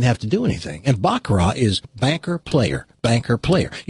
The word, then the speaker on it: baccarat: It's actually pronounced baccarat. Some people call it baccarat. It's French. It's baccarat.